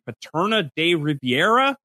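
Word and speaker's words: Paterna de Rivera: The 3 routes test here in Paterna de Riviera. Did I say that right? Jess, you're the smart team. – It sounds right to me.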